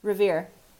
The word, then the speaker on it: Revere: Revere, like Paul Revere. R-E-V-E-R-E? – Yeah. – Revere. – Revere, okay.